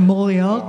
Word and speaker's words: Mauriac: Did I say it right? – Yeah. – Because I only know how to pronounce things when I read them. – I mean, if you could sort of say ho-ho-ho at the same time, that'd be... – Like Mauriac? – Yeah, that's right.